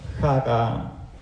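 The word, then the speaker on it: Chara (χαρά): Das heißt Chara. Das X wird ausgesprochen und das wie ein T aussieht, ist ein R im Griechischen.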